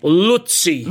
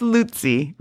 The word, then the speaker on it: Luzzi: So Max von Hippel explained in his newsletter, early in October, Senior Vice Provost David Luzzi, it's a funny name, but L-U-Z-Z-I. – Let's not make fun of names here, okay? – How would you say his name? No, I'm just trying to say it right. – Luzzi.